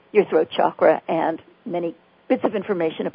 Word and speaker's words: chakra: And then there are those places called chakras, or some people pronounce them chakra. I like chakra because it's more like chocolate.